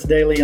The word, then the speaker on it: Doelle: And it's easy to find because my last name is spelled funny. So it's Chris, last name is spelled Doelle. Look for Chris Daly on every social media out there.